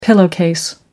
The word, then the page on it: pillowcase: American English